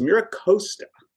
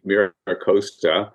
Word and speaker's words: Mira Costa: I unfortunately have to correct you. It's Mira Costa. – Mira Costa is the way at least we butchered the original Spanish pronunciation. – Fair enough. – Right. So the Southern California accent of it would be Mira Costa.